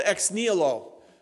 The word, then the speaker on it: ex nihilo: In Latin, it's called ex nihilo. That's the correct pronunciation, because I don't want to make a mistake, 2023.